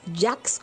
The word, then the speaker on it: Jacques: Se escribe Jacques como Clemente Jacques, Jacques, Offenbach, con doble F, Offenbach.